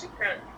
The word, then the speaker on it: shukran: That's shukran.